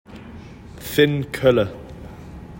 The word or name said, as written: Finn Koelle